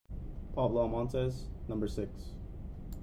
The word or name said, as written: Pablo Almontes